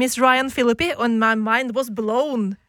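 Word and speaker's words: Phillippe: Ja, og det var jo da Jennifer Love Hewitt, Sarah Michelle Geller, Ryan Philippi, som jeg lærte at man uttaler det, Philippi, uh, eh, Freddy Prince jr.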